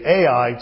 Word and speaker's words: Ai: They came to a little place called Ai, spelled A-I. Some people say Ai just to differentiate it, but it's actually pronounced Ai.